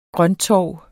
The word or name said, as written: grønttorv